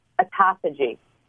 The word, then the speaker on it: autophagy: Autophagy.